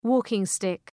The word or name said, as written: walking-stick